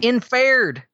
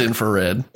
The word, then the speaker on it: infrared: It's infared. – No, it's not. I'm sorry. It's infrared.